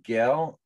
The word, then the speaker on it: Gehl: I talked to Alex Satrowski and a young man who won the main event, a young man named Zachary Gehl, I believe is how you pronounce his name, G-E-H-L.